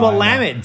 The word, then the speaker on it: Willamette: It, is it Willamette or is it Willamette? Yes. Yeah, I get that a lot. – oh, yeah. It's no, Willamette, damn it.